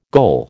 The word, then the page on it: goal: American English